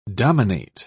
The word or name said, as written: dominate